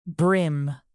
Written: brim

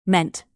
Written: meant

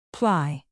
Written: ply

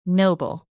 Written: noble

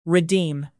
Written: redeem

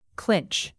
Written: clinch

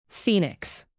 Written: phoenix

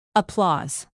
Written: applause

